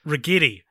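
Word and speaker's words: Ragetti: Rigetti. I know it sounds like spaghetti.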